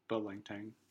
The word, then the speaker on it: Pho Lang Thang: But we decided to get Pho Lang Thang. Tang? – I think it's Pho Lang Tang. – Okay. Anyway, we got that. I know. Well, and Pho is pronounced Pho, but I think in the restaurant name, I pronounce it Pho to kind of differentiate.